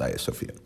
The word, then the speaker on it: Hagia Sophia: It's spelled Hagia Sophia, but it's pronounced Hagia Sophia.